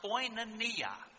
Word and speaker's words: koinonia: And the Bible word, it's a Greek word, it is pronounced koinonia. Koinonia.